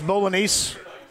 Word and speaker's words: Bolognese: Hey, our friends at Iria's, I may mispronounce this, but my friend Greg says the Pinè Bolognese here is the best individual dish in the city. – Wow. – Did I pronounce Bolognese accurately? – That is, yeah.